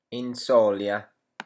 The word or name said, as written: inzolia